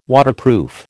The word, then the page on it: Waterproof: American English